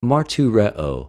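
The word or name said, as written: μαρτυρέω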